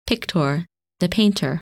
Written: Pictor

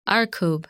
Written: Arkab

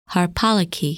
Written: Harpalyke

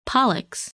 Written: Pollux

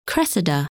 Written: Cressida

